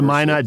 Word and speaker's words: Minot: Is it, how do you say that town? Minot? Mino? M-I-N-O-T? I've read it, but I haven't heard it said.